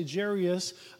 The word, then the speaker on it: Jairus: And again, we say theologically, it looks like it should be Jairus or Jairus, but we say Jairus. I believe that's the correct pronunciation.